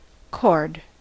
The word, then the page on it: chord: American English